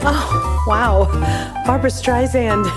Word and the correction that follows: Streisand: Streisand, like sand on the beach, soft S.